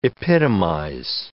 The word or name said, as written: epitomize